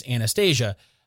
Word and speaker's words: Anastasia: In the movie, it's Anastasia, but it's very possible you pronounce it differently.